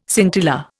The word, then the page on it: scintilla: Indian